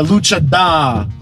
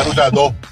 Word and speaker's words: luchador: Yo, he don't pronounce the R Puerto Rican. – Nah, yo. He don't, bro. He's not a luchador. He's a luchada. – He's a luchador.